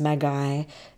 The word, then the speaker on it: Makai: where we stayed at the Makai, which is pronounced Magai, Eco Surf Resort.